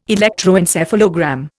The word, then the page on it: electroencephalogram: Indian